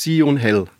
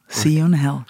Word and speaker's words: Siyou'n Hell: Oder wie spricht man es aus? Siu und Hell. – Ganz genau. – Siu und Hell.